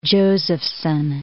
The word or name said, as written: Josephson